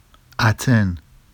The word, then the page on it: aten: Vafsi